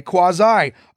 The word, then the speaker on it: quasi: Today it's often pronounced quasi, but it's more correct to say quasi.